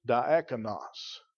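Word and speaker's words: diakonos (διάκονος): Now, the word deacon, it's from a Greek word, and I believe I pronounce it right when I say diakonos.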